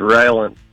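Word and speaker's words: Rylant: It's Ryland. – How do you spell that? – It's R-Y-L-A-N-T.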